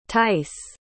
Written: tice

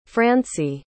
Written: franci

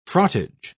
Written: frottage

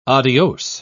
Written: adios

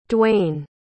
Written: Dwaine

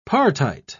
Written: partite

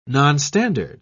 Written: nonstandard

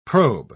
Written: probe

